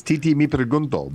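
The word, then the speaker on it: Tití Me Preguntó: It's Titi Mi Pregunto, Bad Bunny.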